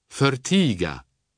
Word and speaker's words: förtiga: Wie im Deutschen wird also folgendermaßen betont: Tiga, schweigen, aber förtiga, verschweigen.